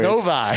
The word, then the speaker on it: Novi: How do you say the city? – Novi. It don't matter.